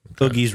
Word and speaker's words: Oogie: Oogie's Revenge. Did I say it right? – Oogie's? – Oogie. Yeah, you got it right for once.